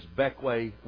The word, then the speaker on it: Bequia: It's spelled B-E-Q-U-I-A, and I understand it's pronounced two or three different ways. But the one that seems that most familiar to us stateside is Beckway, West Indies.